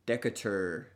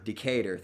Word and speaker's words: Decatur: The Chicago Bears were founded in 1920 when the Staley Starch Company sponsored a football team in Decatur. Pretty sure I butchered that. – Decatur, thank you.